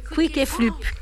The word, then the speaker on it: Quick et Flup: Quick et Flup. – Oui, Quick et Flup.